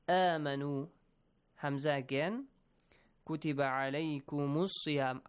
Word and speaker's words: صيام: And siyam, the letter sad, must be pronounced correctly. It's a bit different from the letter sin, right? It has kind of a whistling sound, but not completely whistling sound, okay?